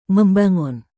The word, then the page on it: membangun: Indonesian